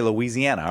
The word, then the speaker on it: Louisiana: You say Louisiana.